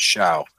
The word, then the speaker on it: Show: Now, remember, Eric Schau, it's spelled Eric Show, kids. Now it's Schau, Schow.